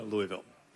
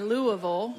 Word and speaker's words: Louisville: How do you like to brag on Louisville? – Well, you got to be able to say it right. So you got to say Louisville. – Louisville. – Not Louisville.